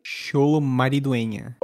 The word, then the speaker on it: Xolo Maridueña: O Fred Stroma, a Viola Davis, o John Cena e o Xolo Maridueña, acertei? – Xolo Maridueña. – Olha, ele tem sotaque, como que é? – Xolo Maridueña.